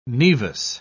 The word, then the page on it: nevus: British English